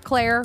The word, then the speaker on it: Claire: Remember, Claire rhymes with bear, so that's how you remember that.